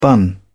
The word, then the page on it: bun: British English